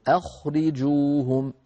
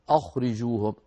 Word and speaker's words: أخرجوهم: من جملة ذلك ما مر معنا في الآية الأولى التي قرأناها، الآية 82، أخرجوهم، وما كان جواب قومه إلا أن قالوا أخرجوهم. أخرجوهم، أخ نرقق الهمزة ونفخم الخاء لأن الهمزة مرققة والخاء مفخمة، فلا نقول أخرجوهم، أخ الهمزة لا تفخم البتة.